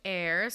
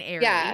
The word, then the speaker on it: Aire: So it's Air, spelled A-I-R-E. She clarified on like a fan account that it's pronounced Air because I was saying Airy.